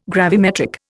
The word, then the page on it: gravimetric: Indian